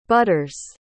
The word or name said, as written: butters